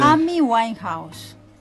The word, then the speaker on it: Amy Winehouse: No, Army Van Heusen no. – ¿Cómo se dice? No me sale a mí el... – Amy Winehouse. – Dígamelo de nuevo, por favor, porque así lo memorizo. – Amy Winehouse.